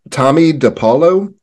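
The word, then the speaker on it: Tomie dePaola: Tommy DiPaolo,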